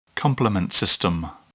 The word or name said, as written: complement system